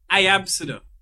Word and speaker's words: Iabcde: And one of the girls' names was, it's pronounced Iabcde. And the spelling is I, A, B, C, D, E.